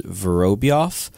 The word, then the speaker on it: Vorobyov: And I'm very interested to see him in this tournament, almost even more so than Rubstov, just because I think the way you're supposed to pronounce his name is Vorobyov, because the general rule with Russian names is that you're supposed to put the accent on the second syllable.